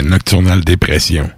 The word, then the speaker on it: Nocturnal Dépression: Peut-être, ah, t'as peu, C'est des Français, là. C'est Nocturnal Dépression. – Nocturnal Dépression. – C'est comme ça qu'ils le ah, prononceraient.